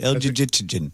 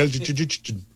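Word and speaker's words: Elgygytgyn: Yeah, I think you had it right. El Jijitinyan. – Yeah, El Jijitinyan, Russia.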